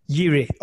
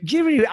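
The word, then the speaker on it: Jiří: Yiri or Jiri. Jiri, I'll accept Jiri.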